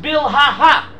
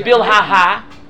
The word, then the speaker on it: Bilhah: It should be pronounced Bilhah. – Bilhah. – We don't pronounce it Bilhah. It's written with an extra hey, but the extra hey is not pronounced.